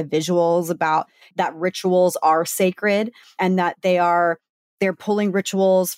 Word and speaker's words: Cecred: And by the way, it's called Sacred, but it's spelled C-E-C-R-E-D, like Beyonce.